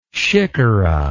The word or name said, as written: sikhara